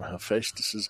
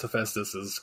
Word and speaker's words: Hephaestus: You pronounce it correctly, but I just rarely... Yeah. I'll explain why I rarely hear Hephaestus pronounced like that.